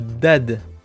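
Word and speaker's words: DAAD: Il s'agit de l'exhumation de Dad.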